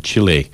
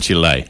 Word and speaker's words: Chile: and then Chile on the 1st of July. – I'm listening to your pronunciation. My pronunciation. – Pronounced Chile wrong. I think it's actually Chile.